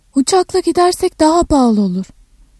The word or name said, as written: pahalı